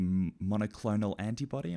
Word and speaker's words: monoclonal antibody: But he invents a wonder drug, and that's one of the monoclonal antibody. Am I saying that right? – You are.